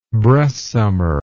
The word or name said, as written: breastsummer